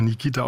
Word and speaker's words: Nikeata: Ich muss das kurz erklären, weil dein Vorname schreibt sich Nikeata, wird aber Nikita ausgesprochen.